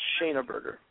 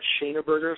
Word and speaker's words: Schoeneberger: but then my dad always pronounced it kind of with that hard A, that Schoeneberger, so. That's kind of what I'm going with right now.